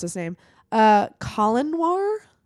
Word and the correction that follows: Colion Noir: but it's spelled C O L I O N, like Colin Noir. – Colion Noir.